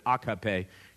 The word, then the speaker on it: AKP: and sometimes I'll even slip into the Turkish pronunciation of it, AKP.